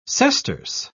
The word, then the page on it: sesterce: British English